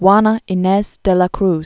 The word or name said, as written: Juana Ines de la Cruz